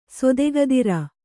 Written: ಸೊದೆಗದಿರ

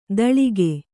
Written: ತಳಿಕೆ

